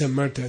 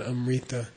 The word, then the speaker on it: Amrita: Everybody mispronounced Sanskrit. And it's important to pronounce it correctly because it's a holy language. It came from the sounds inside the chakras. So if you pronounce it wrong, it clashes with something in your inner body. So anyway, it's Amrita, it's not Amrita.